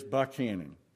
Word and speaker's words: Buchanan: And, yes, if you're not from Georgia, it's spelled Buchanan. It is Buchanan. That's the way we pronounce it around here.